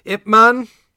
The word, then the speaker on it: Ip Man: Ip Man, as I said, that's my incorrect Cantonese pronunciation of the Mandarin Ye Wen.